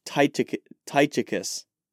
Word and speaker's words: Tychicus: You know, he does mention, oh gosh, I'm going to butcher his name, Tychius. When I really study that portion of Ephesians, I'll get his name right. But we're going with Tychius. Oh gosh, Tychicus.